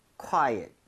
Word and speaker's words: quiet: U의 강세를 좀 찍어서 읽을 때 거기 강세 주면요. ot보다도 at에 가깝게 오히려 발음이 될 겁니다. So 과이. – 약간 quiet, quiet 이런 느낌으로.